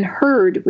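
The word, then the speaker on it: Hurd: My name is Karen Herd, and it's K-A-R-E-N, so the traditional spelling of Karen. And then Herd, which is spelled H-U-R-D. H-U-R-D.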